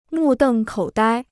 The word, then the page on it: 目瞪口呆: mù dèng kǒu dāi